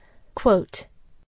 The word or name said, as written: quote